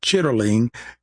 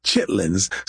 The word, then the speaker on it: chitterling: The even though the word is more accurately pronounced chitterling, we say Chitlins.